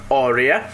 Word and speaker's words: Áurea: Áurea, com pronúncia inglesa Áurea, traz-nos uma melodia do seu primeiro trabalho.